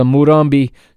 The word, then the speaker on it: Morumbi: Let's go to the lineups at the Morumbi in São Paulo, and I think I'm pronouncing that right. Let me look at that one more time, make sure that I am in fact pronouncing that correctly. It is, yeah, the Morumbi in São Paulo.